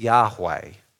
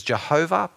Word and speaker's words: Yahweh: Most likely what God's name sounds like is Yahweh. Some people pronounce it as Jehovah, but it's almost certainly not that.